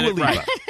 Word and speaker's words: Dua Lipa: You said Dua Lipa.